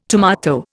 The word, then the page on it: tomato: Indian